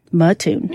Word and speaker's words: Mattoon: How do you spell it? – M-A-T-T-O-O-N. Mattoon. – Yeah, I'd say Mattoon.